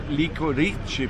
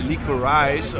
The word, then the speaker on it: Licorice: Der Sprecher vom Deutschlandradio hat den Film betitelt als Licorice Pizza, also hat sozusagen die italienische Aussprache gewählt. Ja. – Dann gab es eine Kritik von Katja Nikodemis, glaube ich, von der Zeit, wo ich gehört habe, die hat gesagt, Licorice, also so die amerikanische Aussprache.